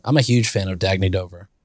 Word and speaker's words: Dagne Dover: Dagne Dover.